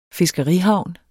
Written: fiskerihavn

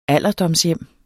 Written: alderdomshjem

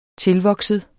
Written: tilvokset

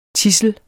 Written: tidsel

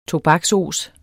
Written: tobaksos

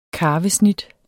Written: karvesnit

